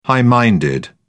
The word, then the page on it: high minded: British English